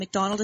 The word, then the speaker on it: McDonald: And McDonald is spelled M-C-D-O-N-A-L-D.